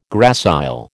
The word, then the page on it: gracile: American English